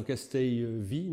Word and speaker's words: Castelvi: En fait, alors c'est un professeur, Castelvi, je ne sais pas comment on dit, parce qu'il est espagnol, Castel, deux L, ça se dit comment? Alors Castelvi, non? Castel, ouais. Ouais, comme ça s'écrit.